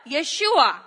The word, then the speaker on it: Yeshua: And that name was, yes, Jesus in English, but Yeshua in the original pronunciation, which means what?